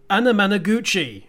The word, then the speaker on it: Anamanaguchi: Suburban Tram there by New York based Nintendo core band Anamanaguchi, and I actually managed to say that right, part of the soundtrack to Scott Pilgrim vs.